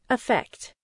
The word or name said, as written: affect